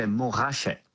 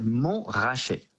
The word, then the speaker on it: Montrachet: Which actually in French, we don't pronounce the T, we say Montrachet. – Oh, really? – Yes, because it comes to the ancient French, basically, which meant the mount that's been shaved. Mont rasé. – Oh. – Mont rasé. Yeah. And so therefore, in French, we do not pronounce the T, we say Mont raché.